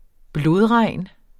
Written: blodregn

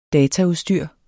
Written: dataudstyr

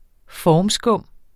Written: formskum

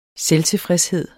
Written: SELVTILFREDSE

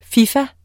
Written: fifa

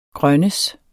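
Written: grønnedes

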